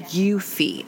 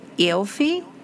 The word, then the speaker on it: Eufy: Get him a Eufy. I think that's how you say Eufy. It's E-U-F-Y. How do you say that? Eufy, right? – Eufy, I guess, yeah.